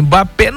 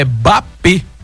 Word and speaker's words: Mbappé: E o Mbappé, que a gente fala Mbappé, é Bapê. A pronúncia correta. E todo mundo fala errado, mas faz parte, viu, Lúcia Sardinha? Você que gosta de viajar muito, pronúncia é Bapê, não Mbappé.